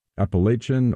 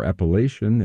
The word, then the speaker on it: Appalachian: Appalachian or Appalachian in the north, and Appalachian or Appalachian in the south.